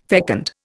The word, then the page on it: fecund: Indian